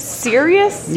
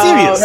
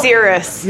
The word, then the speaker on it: Sirius: Is it pronounced Sirius? – Yes. – Yeah. Yeah. – Sirius. – Yeah. – You know, I just always called it Cirrus. – Yeah, that's dumb. That's dumb.